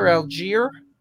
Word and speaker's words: Allgeier: Tyler Allgeier. And yes, Ike, that's how it's pronounced.